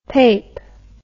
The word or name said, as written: pape